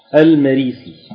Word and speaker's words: المريسي: اقرا عم اسمع المريسي بفتح الميم وكسر الراء وبعدها الياء المنقوطه باثنتين من تحتها وفي آخرها السين المهملة